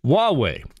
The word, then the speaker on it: Huawei: It has a difficult name to pronounce. – Huawei. – Thank you. Huawei.